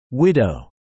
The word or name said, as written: Widow